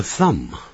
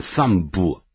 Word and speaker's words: thumb: 好了，那么这个 thumb 那个 b 可不要发音变成 thumb 啊，不要这样念啊， thumb 复数直接加个 s。